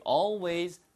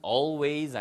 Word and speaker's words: always: You always say that. 발음은 always 아니면 always 다 가능합니다.